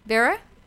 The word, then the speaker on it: Beara: So we have people popping on the comment section saying hello from Beara, B-E-A-R-A. How would I pronounce it? Beara? – Yeah. Beara Brooke.